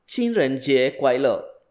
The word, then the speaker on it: 情人节快乐: 情人节快乐. – 情人节快乐.